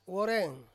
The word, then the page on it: woreng: Ansus